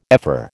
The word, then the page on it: ephor: American English